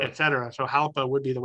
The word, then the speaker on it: Jalpa: Well, Jalpa would be Spanish, so in Italian, it's Jalpa.